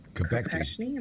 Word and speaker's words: Kopechne: Kennedy and the death of his 28-year-old passenger, Mary Jo Kopechne? Am I saying that right? – Yeah.